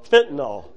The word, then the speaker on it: fentanyl: If I had a vial of fentanyl, is that how you say it? – Fentanyl.